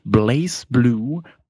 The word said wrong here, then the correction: BlazBlue: Ja, damit Aldo jetzt äh, nicht äh, alle hier ankackt, äh, BlazBlue heißt es. BlazBlue. Oh, guck mich an, ich kann voll das richtig aussprechen.